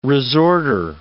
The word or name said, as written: resorter